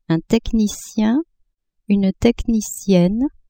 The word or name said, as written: technicien-technicienne